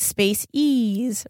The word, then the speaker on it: Spaceez: Am I saying it right? – Mm-hmm. – And it's like Spaceez, right? – Yes, yeah. Yes.